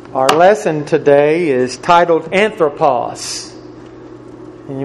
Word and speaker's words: Anthropos: it's A-N-T-H-R-O-P-O-S. Anthropos. Spelling test to follow tonight. It's again A-N-T-H-R-O-P-O-S.